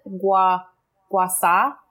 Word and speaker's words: gua sha: She pronounces it gua sha. She doesn't pronounce the H in it.